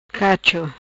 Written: Cacho

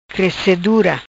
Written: Crecedura

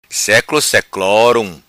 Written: Seclo Seclorum

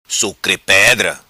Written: Sucre Pedra

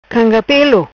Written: Canga Pelo

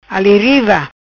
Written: Ali riva

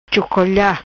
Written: Chocolha

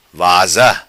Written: vaza

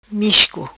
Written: Misco

